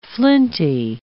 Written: flinty

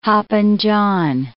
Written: hoppin' John

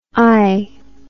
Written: aie

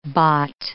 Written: bot

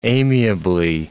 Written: amiably

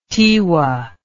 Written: Tiwa

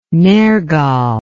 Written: Nergal

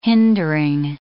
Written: hindering

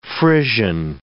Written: Frisian

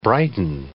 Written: brighten

